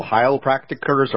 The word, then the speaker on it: Heilpraktiker: There are what they call heilpraktikers or heilpraktitioners. How do you say that word? – Heilpraktiker. – Yes.